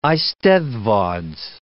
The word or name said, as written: eisteddfods